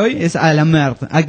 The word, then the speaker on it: merde: Bueno, y dice, solo díganle a la merde, Se pronuncia merde.